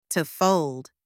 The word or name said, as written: to fold